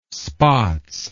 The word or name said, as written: spaatz